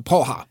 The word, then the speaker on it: Praha: Så, alltså, man säger inte Prag, utan man säger Praha.